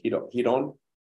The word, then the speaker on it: Ryron: The R is pronounced as an H. So Hiron, and then Gracie, Gracie.